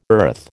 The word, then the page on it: earth: American English